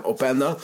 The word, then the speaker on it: Openda: Vervolgens wordt Rensch aan de andere kant eruit gelopen door onze vriend Openda, als ik het goed heb. Ja, zeg ik het goed? Ja, Openda. En die maakt hem makkelijk af.